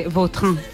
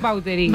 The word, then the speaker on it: Vautrin: Pues, es que si me pregunta exactamente cómo se pronuncia, pues así. Claro, pero sí. Yo ya soy Vautrin. – Ya. – Está españolizado total.